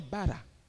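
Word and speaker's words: bara (ברא): Le mot « créer » ici, c'est le mot « bara » que l'on prononce en hébreu « bara ». B-A-R-A. Le mot « bara », vous savez ce que ça veut dire ?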